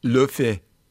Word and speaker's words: Laufey: But have you ever come across Lofi? That's how you pronounce it in Iceland.